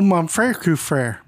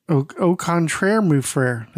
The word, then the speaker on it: Au contraire, mon frère: Oh, mon frère, coup frère. That's how you say it, right? – What's that? – Au contraire, mon frère. That's it.